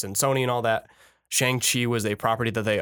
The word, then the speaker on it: Shang-Chi: Shang-Chi. – S-H-A-N-G-Chi. – And then Chi, C-H-I.